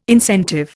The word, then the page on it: incentive: Indian